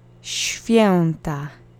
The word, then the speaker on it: Święta: The accent is on the first syllable. Święta. Święta. Święta. Note that the W should make a V sound, but SHV is incredibly hard to say, so in natural speech it gets softened to a SHF sound, like an F.